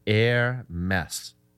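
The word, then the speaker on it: Hermès: That the company name is pronounced Air Mess.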